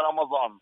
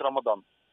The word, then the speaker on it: Рамадан: Але коли деякі народи читають арабську букву «да», то вони її читають як «за» і вимовляють не Рамадан, а Рамазан. Тобто от від цього і пішла різниця у назві, хоча насправді місяць має називатись Рамадан.